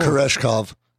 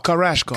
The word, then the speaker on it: Koreshkov: Andrei Korshchikov. I butcher his last name all the time. But Andrei Korshchikov. – All right, cool. – Koreshkov. – Oh, Koreshkov.